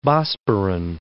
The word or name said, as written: Bosporan